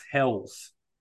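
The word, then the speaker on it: Helles: You've gone for the Hellers this evening. – I'm told it's pronounced Hells.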